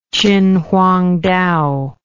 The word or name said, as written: chinhuangtao